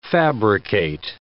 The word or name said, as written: fabricate